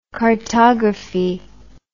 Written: chartography

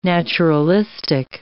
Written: naturalistic

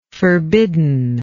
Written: forbiddenness

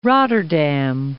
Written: Rotterdam

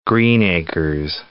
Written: Greenacres

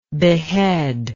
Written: beheader

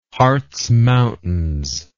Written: harz mountains

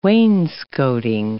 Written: wainscotting

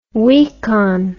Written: weakon

